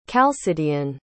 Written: chalcidian

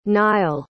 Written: nile

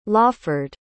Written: lawford

